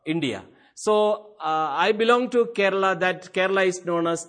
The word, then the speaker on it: Kerala: I am from Kerala. K-E-R-A-L-A. Recently I said this name to someone, and he pronounced it in some other way, so I felt a little bad about it.